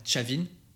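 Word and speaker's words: Trà Vinh: Ces zones-là, je pense notamment à Chavin, à Sok Chong et à Chiton. Alors ça s'écrit T-R-A plus loin V-I-N-H, S-O-C plus loin T-R-A-N-G, ou Chiton, ça c'est carrément un district dans une province T-R-I plus loin T-O-N.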